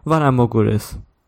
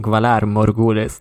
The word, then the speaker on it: Valar Morghulis: I was actually, like, on the show when they first said Valar Morghulis, I was, like, so disappointed by the way they pronounced it, because she's just like, Valar Morghulis. But I always thought it would, like, Valar Morghulis, like, you know, roll those R's for this.